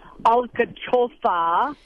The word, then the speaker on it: alcachofa: And I mean, we had artichokes in everything, and I even learned how to say it in Spanish, which is pronounced something like alcachofa.